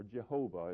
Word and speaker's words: Jehovah: And again, he's referred to as Yahweh did all of this, or Jehovah is how some would pronounce it, and how many people today do pronounce it.